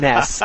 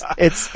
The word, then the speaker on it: NES: Okay, I remember that's actually, if we go back to our first conversation at Denny's, I didn't have the heart to correct you when you said mess. I was like, I thought in my head, did he really just say mess? – I can just see the hate mail flowing in now. – Yeah, it's NES. It's NES.